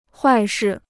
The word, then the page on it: 坏事: huài shì